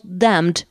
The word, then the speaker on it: damned: En fait, on prononce « Damned », on prononce très, très légèrement le « D » de la fin.